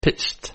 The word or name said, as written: pitched